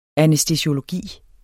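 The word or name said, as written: ANÆSTESIOLOGI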